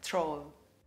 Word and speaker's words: troll: Again, the emphasis is on the O, not the R. So the O is the big sound. Troll.